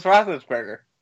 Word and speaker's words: Roethlisberger: Burger Rothless Roethlisberger.